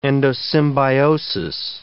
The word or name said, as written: endosymbiosis